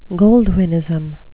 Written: goldwynism

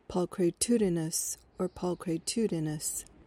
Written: pulchritudinous

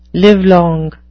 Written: livelong

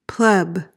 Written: pleb

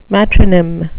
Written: matronym

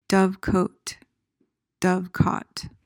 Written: dovecote

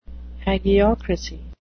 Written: hagiocracy

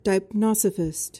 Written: deipnosophist

